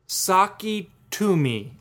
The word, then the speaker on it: Sakitumi: It's called the Saki Tumi, and it's spelled S-A-K-I-T-U-M-I. So, well, you're like, what? That doesn't sound like Saki Tumi.